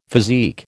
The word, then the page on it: physique: American English